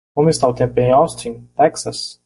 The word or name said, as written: Texas